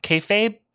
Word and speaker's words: kayfabe: It's a kayfabe. Kayfabe? Kayfabe, I think is the way it's pronounced.